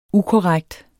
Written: ukorrekt

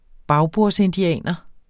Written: bagbordsindianer

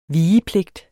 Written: vigepligt

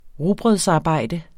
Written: rugbrødsarbejde

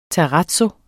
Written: terrazzo

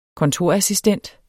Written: kontorassistent

